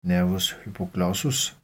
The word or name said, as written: n. hypoglossus